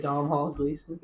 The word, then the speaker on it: Domhnall Gleeson: Domhnall Gleeson? – Yes. Pretty much just them. But it's... it's a really good show. Steve Carell's a therapist. Whoever you just said, I can't pronounce it. – Domhnall Gleeson? – Yeah.